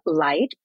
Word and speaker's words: Light: My name is Etelle Light. It's pronounced light, like the light.